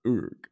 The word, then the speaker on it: Uruk: How about we talk about orcs or Uruk? Right. Did I say that right? – Mm-hmm. – Uruk.